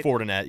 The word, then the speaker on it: Fortinet: Fortinet Championship, if I'm pronouncing that right. – Fortinet, yeah.